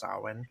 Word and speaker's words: Samhain: And then, of course, the season ends with Samhain, which is spelled Samhain, but pronounced Samhain.